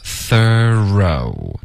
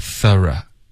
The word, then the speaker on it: thorough: OK、所以第一个 thorough 这是一个美式的发音 OK、第二个 thorough 这是一个比较英式的发音 OK、那就看你了，你喜欢美式发音还是英式那么第三个呢？